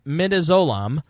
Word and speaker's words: midazolam: The lethal injection consisted of med- Medallizone. I should have looked how to how to uh, actually pronounce this. Uh, mid midazolam. Yeah, midazolam. That's how what we'll go with.